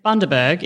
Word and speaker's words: Bundaberg: I think it was like Bundaberg, right, if I'm not wrong, which sounded very German. – I don't remember. – You don't remember? – No, sorry. – So Bundaberg is how you say it.